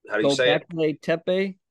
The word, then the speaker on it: Göbekli Tepe: And you hear a lot about this with, I'm not going to say it right, Göbekli Tepe.